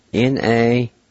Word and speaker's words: nahiyu: And it's spelled U-N-V-S-A. U-N-V-S-A. Number 5, and the last word for today is then. And it's spelled N-A-H-I-Y-U. N-A-H-I-Y-U.